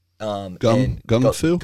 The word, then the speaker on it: gung fu: Gung Fu? – Gung Fu is pronounced with a G.